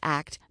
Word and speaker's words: ACT: ACT, such as ACT Made Simple, ACT Made Simple Second Edition, Learning ACT. ACT, pronounced as the word ACT, not as A-C-T, has its roots in Applied Behavioral Analysis, ABA, and Relational Frame Therapy, RFT, which is a theoretical approach to language and cognition.